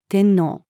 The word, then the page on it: 天皇: female